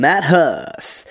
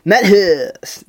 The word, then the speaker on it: Matt Hoss: I'm Matt Hoss. That's how you say my name. If you ever go to mention me in public, go, "Oh, have you seen that brand new comedian? Matt Hoss!" Do it like that, please.